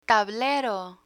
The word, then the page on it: tablero: Spanish